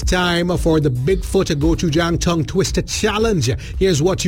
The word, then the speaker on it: gochujang: Introducing the new Bigfoot gochujang flavor. But before you can say it, you've got to learn it. It's easy, three syllables. Gochujang.